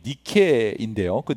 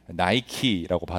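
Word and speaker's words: 니케인데 (Νίκη): 원래 발음은 니케인데 이것이 영어 발음으로 미국 사람들은 하도 굴리니까 나이키라고 발음을 하죠.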